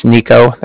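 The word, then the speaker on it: Niko: My name's Nico. That's N-I-K-O. I'm calling with the Bank of Cardiff, and Cardiff is spelled C-A-R-D-I-F-F, as in Frank Frank.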